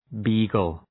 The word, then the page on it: beagle: ‘bi:gəl